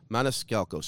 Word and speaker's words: Maniscalco: How does say, how do you say the uh, Maniscalco? Maniscalco.